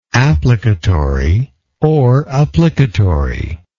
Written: applicatorily

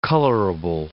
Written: colorable

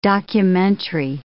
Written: documentary

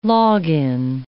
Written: log-in

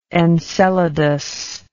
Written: enceladus

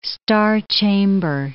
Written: star-chamber

